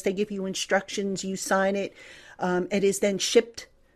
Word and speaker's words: SIGND: So this is a new initiative, and it's spelled SIGND.